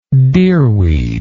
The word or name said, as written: deerweed